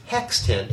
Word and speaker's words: x10: It's like, is this pronounced, do you want me to say times 10 or x10? And he actually texted Sorkin, who wrote the script, and it came back, oh, the proper pronunciation is hex 10, H-E-X.